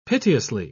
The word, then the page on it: piteously: British English